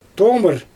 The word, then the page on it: toomɯr: Xyzyl